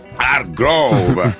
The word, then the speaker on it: Hargrove: Hargrove. J'espère que j'ai bien prononcé. – Hargrove.